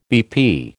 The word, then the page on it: BP: American English